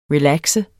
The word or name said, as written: relaxe